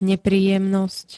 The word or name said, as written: nepríjemnosť